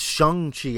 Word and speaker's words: Shang-Chi: I think it's Shang-Chi, actually, even though it's an A. I think Shang? It's Shang-Chi. – Shang? – Like that. – Like pronounced like an O? – Pronounced like maybe a U. – Shang. – All I know... It's not pronounced like an A, I believe, if you listen to the trailer.